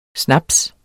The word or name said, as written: snaps